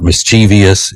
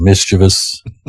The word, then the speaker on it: mischievous: Actually, I just wanted to say mischievous because I want people to understand that's the proper way to pronounce the word. It's not mischievous. It is mischievous.